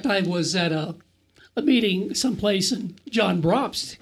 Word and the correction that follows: Brobst: came up to me. He said, Oh, John Brobst. – Yeah, Brobst. Jack. I'm sorry, yes. I'm okay. pronouncing his name wrong.